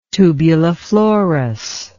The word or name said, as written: tubuliflorous